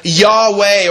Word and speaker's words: Yahweh: If we were to pronounce this from the Hebrew, you get either Yahweh or Jehovah, depending on how you place the vowels in the Hebrew word.